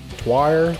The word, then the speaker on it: Tyr: T-Y-R, guys. Go look it up. – Yeah, we actually got listened to some interviews of them pronouncing their name, so we tried to get the name pronounced right.